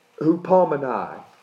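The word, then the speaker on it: hupomenei: The Greek for endures in this verse, and I'll butcher it, but is hupomenai. H-U-P-O-M-E-N-E-I. H-U-P-O-M-E-N-E-I.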